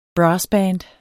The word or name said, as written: brassband